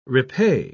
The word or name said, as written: repay